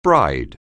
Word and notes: bride: anglais

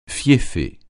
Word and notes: fieffé: francais

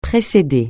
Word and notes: précéder: francais

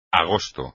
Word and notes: agosto: espagnol

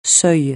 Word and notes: seuil: francais

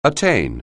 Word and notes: attain: anglais

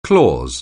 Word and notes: clause: anglais

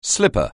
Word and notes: slipper: anglais

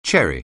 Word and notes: cherry: English

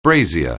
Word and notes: brazier: anglais

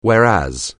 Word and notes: whereas: anglais

